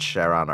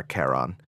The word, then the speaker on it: Charon: By the way, the pronunciation, because I'm obsessed with the concept of him, the ferryman, the translation is debated on whether it's Charon or Charon.